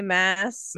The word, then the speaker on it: Maas: I, I usually say mass, mass. but you know, what, Sarah Moss, Mass.